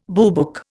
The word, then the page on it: boobook: Indian